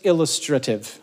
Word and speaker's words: illustrative: But Paul mentions it to say, I'm sure we could make longer lists, but these are just illustrative. Illustrative. You got to put the emphasis on the right syllable.